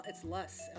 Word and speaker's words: loess: Silt and loess are gonna, I don't know if I'm saying that right, loess. – I think I have a pronunciation here. It's luss, L-U-S-S.